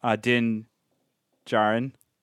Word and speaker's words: Din Djarin: I would like to see a flashback in terms of I could see a cross between young Din Djarin, right? That's how you say it.